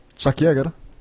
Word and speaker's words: Chuck Yeager: Passiamo al 14 ottobre del 1947, quando Chuck ecco, questa è la pronuncia, forse la dite meglio voi. – Chuck Yeager? – Ecco, bravo, no, quindi mi sfugge, mi scappa sempre, non so perché.